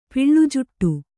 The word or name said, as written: ಪಿಳ್ಜುಟ್ಟು